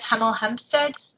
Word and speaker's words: Hemel Hempstead: But I have my good friend James Prowse here from, I guess, Hemel Hempstead.